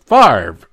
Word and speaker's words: Favre: Brett Favre's name does not spell Favre. It's Favre. But you call it, it's pronounced Favre for some weird reason. It's one of those rare instances where you pronounce the letter after the other one first, but you pronounce both. Screwed up.